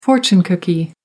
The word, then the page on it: fortune cookie: American English